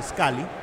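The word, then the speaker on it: Scally: Sí, parecido, solo que con A en vez de U en este caso. Pero bueno, se pronuncia igual, Scully.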